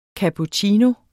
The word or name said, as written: cappuccino